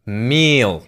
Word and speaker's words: Miele: And it's not exactly pronounced wrong in English, but English native speakers usually say something like Miele, Miele. So it usually sounds more like an A in the end, whereas in German we say Miele.